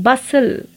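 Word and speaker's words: bustle: बसल T is silent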